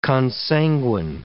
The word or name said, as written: consanguine